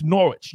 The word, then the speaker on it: Norwich: It's Norwich. You don't say the W.